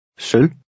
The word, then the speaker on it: sølv: Remember the silent V. – Sølv.